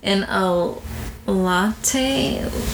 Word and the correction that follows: Lotte: LOTTE. Lotte Water Park CF.